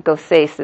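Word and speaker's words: goses: And it's samech, it's not taf, so it's not like Shabbat. Gosais is how you pronounce it.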